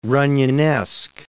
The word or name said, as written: Runyonesque